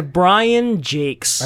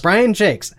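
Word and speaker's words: Brian Jacques: By Brian Jakes. – By Brian Jakes. A name that does not look like it's pronounced that way.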